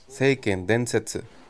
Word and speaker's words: Seiken Densetsu: You're pronouncing the... You know Japanese, so you're pronouncing that really weirdly. – Seiken Denetsu? – Seiken Densetsu.